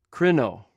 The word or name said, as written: krinw